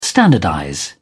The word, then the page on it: standardize: British English